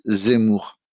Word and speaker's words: Zemmour: Du har ju redan nämnt han, Eric Zemmour. Mm. Är det rätt uttal? Får jag bassning för uttalet? – Zemmour.